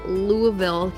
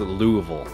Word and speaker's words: Louisville: We're gonna jump from Louisville, Kentucky, over to Newfoundland in Canada. – Just so you know, it is pronounced Louisville.